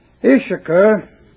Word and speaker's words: Issachar: Issachar, if you're interested in the Hebrew, where you have the double S, one of them has the value of SH and the other of the S. So the word is pronounced Issachar, is a strong ass couching down between two burdens.